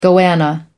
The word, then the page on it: goanna: American English